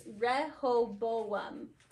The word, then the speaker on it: Rehoboam: I think they were just kind of saying, like, now, also the whole, like, Rehoboam, whatever, the Rehoboam, I hated It took me until watching an explanation video to be able to get the pronunciation. It's Rehoboam.